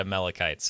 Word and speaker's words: Amalekites: apparently you can say it Amalekites, so that's what I'm going to say.